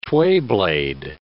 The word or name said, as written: twayblade